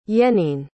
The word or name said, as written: yenene